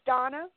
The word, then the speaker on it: Donna: Because you had somebody who just called you, of all things, Donner. Not Donna. He said Donner because it's the way he pronounced it. Do you understand, like, he would pronounce his A at the end like an R? Like, instead of hundred, he would have said hunnert. And instead of Donna, he says Donner.